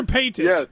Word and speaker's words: Paytas: Trisha Paytas.